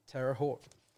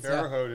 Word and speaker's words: Terre Haute: Sarah Messing-Stern, Terre Haute, Indiana. So I think, did I pronounce that correctly? – Yeah, yes. – Terre Haute, Indiana.